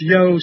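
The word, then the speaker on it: Io: The Yo is spelled I-O, but it would be pronounced Yo Saturnalia.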